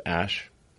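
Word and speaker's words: Æ: I mean, it's just X, the letter X, um, and then the AE is like pronounced ash.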